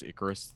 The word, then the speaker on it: Icarus: Is that Icarus or is that how you Icarus. – Pronounce Icarus? Boy.